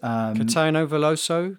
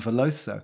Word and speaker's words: Caetano Veloso: Caetano Veloso. – That's the man, Veloso.